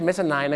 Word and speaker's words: mezzanine: mezzanine ไม่ใช่ mezzanine นะครับ mezzanine เหตุผลที่เขาต้องออกเสียงว่า nine